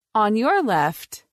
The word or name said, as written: On your left